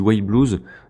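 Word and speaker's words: Y-Blues: Retour des pauses musicales, on va s'écouter du White Blues. Donc ça s'écrit Y.blues.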